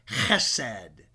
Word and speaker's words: chesed: It is chesed. Chesed. That's how the Jews pronounce it. It is spelled H-E-S-E-D.